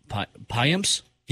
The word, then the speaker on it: Payamps: Payamps.